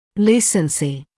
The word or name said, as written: lucency